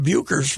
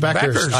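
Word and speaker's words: Bueckers: Until I listened to the broadcast, I'd been pronouncing her name Buechers for 2 years. I didn't know it was Beckers.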